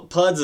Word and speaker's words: PUDs: Well, PUDs is an acronym, yeah, and you said it right.